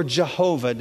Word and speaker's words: Yahweh: It's called the Tetragrammaton, and it's pronounced Yahweh, we think, or Jehovah, depending on the alphabet that you use.